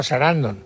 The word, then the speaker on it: Sarandon: Susan Sarandon.